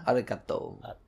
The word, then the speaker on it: Arakado: Genya Arakado. – Arakado? – Arakado. Arakado. – You've said that now. – Well, it's like arigato. – Yeah. – But it's Arakado.